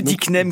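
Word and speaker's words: œdicnème: Le dicnème criard. – Voilà, celui-ci est quand même assez particulier, je trouve. Alors, dicnème, ça s'écrit O-E collé, et ça vient du grec œdéma, qui veut dire œdème.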